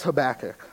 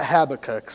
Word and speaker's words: Habakkuk: First thing is it's pronounced Habakkuk, all right, not Habakkuk.